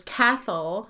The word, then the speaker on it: Cathal: Um, there's Cathal, which I will s- spell because it sounds like cattle.